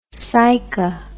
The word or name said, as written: saiqa